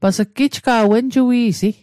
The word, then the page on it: wenjui'sit: wen'jui'sit; Mi'gmaq